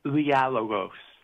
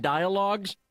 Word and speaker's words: Dialogos: Well, in Greek, it's pronounced dialogos. – Oh, well, I wasn't. – See, we were saying we're dumb Americans. So we've been saying dialogues, but I guess it's from, I guess dialogue is from the Greek word.